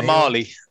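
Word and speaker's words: Mahle: How do you say his last name? Tyler Mahill? – Tyler Marley. – Marley. Oh, there you go.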